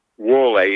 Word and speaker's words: Whaley: Whaley, he's pronounced Whaley.